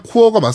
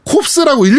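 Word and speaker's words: Corps: 그리고 오늘 AS 엄브렐라 코어는 발음상 코어가 맞습니다. 라고 하셨는데 정확히 찝으셨습니다. – 예, 저희가 함정을 봤거든요 네, 저희가 함정을 팠습니다. 저희가 네. – 콥스라고 일부러 그랬어요.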